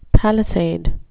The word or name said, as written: palisade